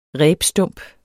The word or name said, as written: rebstump